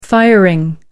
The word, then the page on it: firing: American English